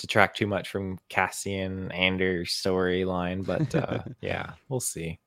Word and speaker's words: Andor: Ander is the way he pronounces the OR. He said another word today, too. It wasn't Andor. It was something else with the OR, and he just did the classic Skarsgård.